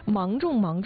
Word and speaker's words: 芒种: 其实芒种的芒啊，它指的就是植物籽儿这个果实啊，这个外壳上面的这个细刺。那更准确的说呢，芒是指一些有芒的作物，而种既指的是这个种子的种，也是播种的种啊，是个多音字呢。所以说呢，就是芒种嘛，就是连收带种，它既是成熟的终点，也是新生的起点。